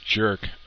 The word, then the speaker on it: JIRC: J-I-R-C is pronounced jerk. Okay, if you say so, Dragon. Jerk. Not you, but the way you pronounced it.